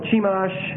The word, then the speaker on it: Chemosh: And Chemosh, C-H-E-M-O-S-H, Chemosh, we'll pronounce it Chemosh, was the chief god of the Moabites.